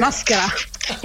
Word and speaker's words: Maschera: Is La Grande Fiesta in Maschera? – Maschera. It's almost like Maschera. – Maschera with a hard C.